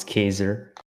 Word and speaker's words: Khaizer: Sup, it's Khaizer, but sup. – Fuck, what's up, guys? – It's Khaizer and Harlee, guys. Say what's up. – Sup. He pronounced my name wrong. It's Khaizer.